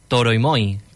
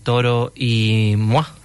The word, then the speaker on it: Toro y Moi: Tengo que reconocer que hasta ayer yo mismo lo pronunciaba Toro y Moi, como si fuera Moisés y fueran dos. Pero al parecer su nombre viene de la palabra en español Toro y la francesa Moi, que significa yo.